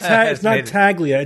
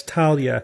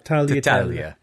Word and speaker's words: tagliatella: It's not Taglia. It's Talia. – Talia. – Yeah. GL is like LY. Tagliatella.